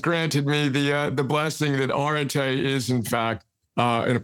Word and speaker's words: Areté: Yeah, I like to pronounce it Erdete. It's debatable.